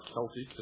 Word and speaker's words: Celtic: Examples are ancient Mexican, Mayan, Inca, Aztec, Sumerian, Babylonian, Assyrian, Egyptian, Celtic, are Celtic, as some pronounce it, Aryan, etc.